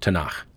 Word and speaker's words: Tanakh: And that's the letters T, N, K, which you pronounce Tanakh.